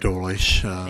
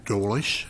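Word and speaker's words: Dolejsi: Madam Chair, it's Frank Dolish. You were actually pretty close. So. And I don't know how you get that pronunciation out of it either. Madam Chair, committee members, my name is Frank Dolish. That's D-O-L-E-J-S-I.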